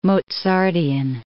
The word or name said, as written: Mozartian